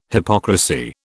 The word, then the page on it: hypocrisy: British English